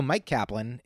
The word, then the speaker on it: Myq Kaplan: Um, so Myq Kaplan is my name,